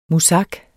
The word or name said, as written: muzak